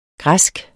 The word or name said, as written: græsk